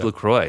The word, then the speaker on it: LaCroix: It is LaCroix. – It's LaCroix. – Because it's rhymes with enjoy.